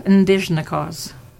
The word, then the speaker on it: Indigenous: So I'm going to teach you the word Indigenous, and that, because when I would say Indigenous, so you could try that word Indigenous. – What's the sound at the end? – It's like a Z. Indigenous.